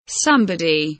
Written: somebody

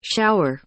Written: shower

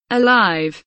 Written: alive

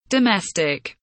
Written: domestic

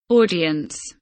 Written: audience

